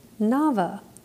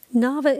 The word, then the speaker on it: Naba: Last time in our podcast, we discussed the prophetic word Naba, spelled N-A-B-A, but it's pronounced N-A-V-A.